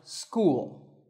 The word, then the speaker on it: school: This word is pronounced school. You see that the written form has six letters, S-C-H-O-O-L, but the spoken form has four sounds, S, K, U, and L.